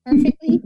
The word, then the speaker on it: Purrrfectly: Perfectly, perfectly. is that what it is? Am I saying it right? – Perfectly. – Yep. That's Good.